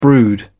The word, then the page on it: brood: British English